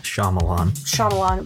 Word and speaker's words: Shyamalan: I don't know how to say his last name properly. – Shyamalan.